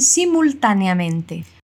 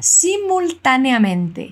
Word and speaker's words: simultáneamente: Pero quiero que te fijes en la pronunciación de una palabra. Simultáneamente. La L tras la U tiene sonido de L.